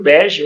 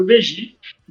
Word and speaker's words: BeGi: É, a, a pronúncia ela tanto pode ser como Fábio Bege ou Fábio Begi, né?